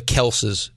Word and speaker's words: Kelce: The Kelces. It's Kelce, though. – No. – I'm just telling you how it's supposed to be pronounced.